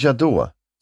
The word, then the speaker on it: Jadot: Jadon. Louis Jadot.